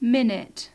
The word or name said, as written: minute